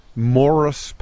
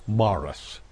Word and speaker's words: Maurice: I'm named Morris Patrick Cashman. – Not Morris. – M-A-U-R-I-C-E. – That's pronounced, Maurice. – Well, it looks like Maurice, but it's if you're Irish, it's pronounced Morris.